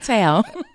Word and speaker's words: Tal: Did I say her name? Okay, you say that. – Tal.